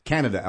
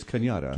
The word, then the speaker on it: Cañada: and you see this little cutoff for Cañada, it says Canada Alamosa. – Yeah. Mm-hmm. – It's pronounced locally as Cañada.